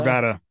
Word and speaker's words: Arvada: Am I saying that right? Or Arvada? – Arvada. Yep. Arvada, Colorado.